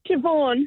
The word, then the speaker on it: Siobhan: It's S-I-O-B-H-A-N. – Oh, isn't it pronounced Siobhan? – Siobhan. – Yeah, it's just Siobhan. – Why would you go out there and spell it S-I-O-B-H-A-N? – Yeah.